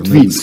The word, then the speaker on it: Путвинский: Путвинский склонился над гробом, Путвинский он, наверное. – Путвинский, Скорее всего, да. польская фамилия, видимо, да? Ударение на предпоследний.